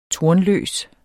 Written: tornløs

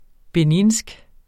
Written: beninsk

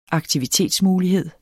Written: aktivitetsmulighed

